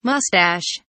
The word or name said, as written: moustache